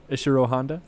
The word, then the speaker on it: Ishiro Honda: Ishiro Honda.